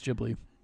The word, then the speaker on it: Ghibli: I like my that is the way they pronounce it, is Ghibli.